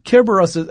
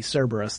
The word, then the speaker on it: Kerberos: Kerberos, that's the Greek pronunciation for, we often will make it a soft C, Cerberus, the three-headed dog.